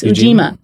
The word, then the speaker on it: Ujima: So I knew to ask you about other initiatives, and I don't know if I pronounced it, Ujima? – Yes, Ujima.